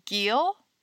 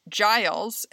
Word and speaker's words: Giles: Is this the story of Giles Corey? – Yes. Is that how you pronounce it, yes. Giles? – Yes. – Okay. I was reading it as Giel.